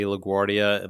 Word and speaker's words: Laguardia: And of course, on social media, you can find me at Dr. Jay Laguardia, and the last name is spelled LAGUARDIA.